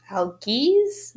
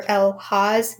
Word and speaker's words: Algiz: So I picked the rune for the day, and I believe the rune is it's pronounced Algiz, which I think is traditionally the rune that's associated with the letter Z. – Oh, good one. – So here, let me just find it. It'll take a minute. Okay, here it is. Yeah, Algiz or Elhaz. Yeah, it's pronounced different ways, but the translation is protection, defense, and opportunity.